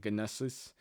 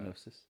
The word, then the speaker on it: gnosis: I'm not even gonna try to say gnosis. Uh, gnosis. – Oh, maybe no, it is gnosis. Gnosis uh, or gnosis. I don't know. The G is silent.